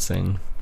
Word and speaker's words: Seng: It's just my my name, Jarrad Seng.